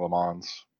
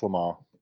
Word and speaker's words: Le Mans: I probably spent 20 years saying Le Mans, but I mean, it's Le Mans.